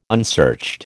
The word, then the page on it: unsearched: American English